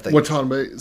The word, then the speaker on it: Watanabe: Watanabe.